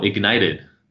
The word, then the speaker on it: IGNTD: and now Ignited, which is spelled IGNTD.